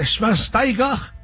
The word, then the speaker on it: Schweinsteiger: Y un día como hoy, 4 de agosto, pero de 1984, tiene mi edad, nace Bastian Schweinsteiger, uno de los mejores mediocampistas alemanes de su generación.